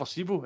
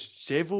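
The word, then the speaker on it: Šebo: Was never Sebo, it was always Sevo or something. The B was pronounced as a V.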